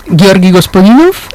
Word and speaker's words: Georgi Gospodinov: Georgi Gospodinov, ¿sí? – Zdravka Eftimova y Vera Mutavcheva.